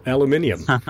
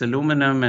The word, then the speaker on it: aluminum: In that regard, then, maybe on a very pedestrian note, what is the correct way to pronounce it? Aluminum or aluminium? – Yeah, so this is that, you know, divide across the Atlantic. So certainly in the US, it's aluminum, and in the rest of the world, it's aluminium.